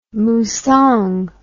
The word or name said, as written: musang